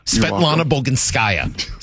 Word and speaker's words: Svetlana Boginskaya: Svetlana Boginskaya.